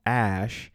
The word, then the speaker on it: Æ: Asked about the pronunciation, Musk broke it down as follows It is just X, the letter X, and the AE is pronounced ash, and then A 12 is my contribution.